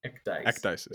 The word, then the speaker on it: Ecdysis: All right, do you want to tell me a bit about the work you're doing at, how is it pronounced? Ecdysis? – Ecdysis. Yeah, you got it.